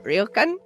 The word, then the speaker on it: ryokan: But anyway, I stayed in a, how do you say it? Ryokan? – Ryokan. – Yeah, that one.